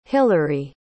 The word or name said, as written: hillery